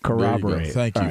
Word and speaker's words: corroborate: Corroborate. Thank you, Brady. So there's an R that's there.